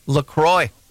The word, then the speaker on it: LaCroix: Officially, it's pronounced LaCroix.